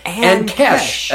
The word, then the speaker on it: Ankesh: Ankesh.